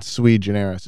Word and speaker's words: sui generis: She was it was sort of sui generis.